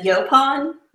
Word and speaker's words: yaupon: I was curious about the yaupon. Is that how you pronounce it? – Yeah.